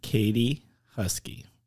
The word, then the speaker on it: Katie Huskey: And so, without further ado, this week's guest is Katie Huskey.